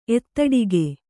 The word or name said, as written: ಎತ್ತಡಿಗೆ